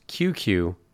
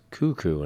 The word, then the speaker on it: Cucu: That was Cuckoo, which originally I would pronounce QQ because of C-U-C-U, and then I had the pleasure of interviewing a long time ago Miles George, and he said, no, it's Cuckoo, and I was like, oh, sorry for doing that a couple times.